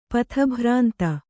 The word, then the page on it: ಪಥಭ್ರಾಂತ: patha bhrāntga